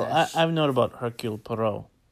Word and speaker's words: Hercule Poirot: We saw the first installment of this Hercule Poirot series.